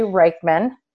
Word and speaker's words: Reichman: Um, so today we have Tori Reichman.